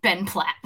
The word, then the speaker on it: Ben Platt: You've missed, you mispronounced Ben Platt's name. You want to take that again? – Okay, sorry. Ben Platt.